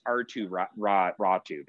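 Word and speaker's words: Aatu Räty: And then I go, well, first of all, it's Atu Ratti, bro. First and foremost, you're saying it wrong, idiot.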